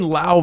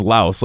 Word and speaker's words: Lao: It's like saying Lao versus Laos. Like, it is... Like, if you go to Lao, like, that is what it is. It's Lao. Like, that's how they pronounce it. But, I mean, in America, like, it's known as Laos.